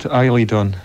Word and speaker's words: Eiledon: That track's called Eiledon.